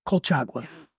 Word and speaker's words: Colchagua: And then, am I saying this right? Colchagua? Colchagua. Yes. And that's a hot spot for kind of like the bold red.